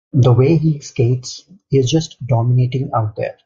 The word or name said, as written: dominating